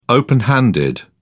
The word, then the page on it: open handed: British English